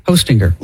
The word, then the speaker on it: Hostinger: But it's hosting-er. – Yeah, that's just how you spell it. – But it's like hosting, and then you put an E-R at the end.